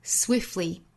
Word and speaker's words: swiftly: 后面 then swiftly move on to the next thing，后面半句有一个词比较难发，就是 swiftly 这个词，我们按照原声的主播这个读法把这个 t 的发音略掉，读成 swiftly swiftly flee flee，相当于没有 t 的这个发音了，这样的发音方式是更简单的。